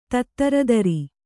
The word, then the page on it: ತತ್ತರದರಿ: tattaradari